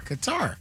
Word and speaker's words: Qatar: K-A-H-T-A-R. – Qatar. – Qatar. So it is Qatar, so not Qatar.